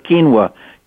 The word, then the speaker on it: quinoa: The plant is called quinoa, pronounced as quinoa, Q-U-I-N-O-A.